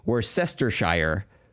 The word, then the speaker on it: Worcestershire: so I like it looks like it should be Worcestershire, mm-hmm. but I know it's like is it English or something right. where they pronounce shire as sure.